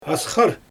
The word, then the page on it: asxyr: Xyzyl